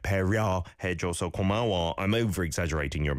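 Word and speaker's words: Komawa (고마워): Sounded at the end like komawa, but it's komawa, an O vowel sound to an A vowel sound.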